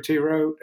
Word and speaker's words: Thoreau: So I think that Henry Thoreau, Sarah, help me out on pronouncing his name.